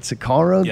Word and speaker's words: Sicario: Sicario. Is that how you say it? Sicario? – Yes.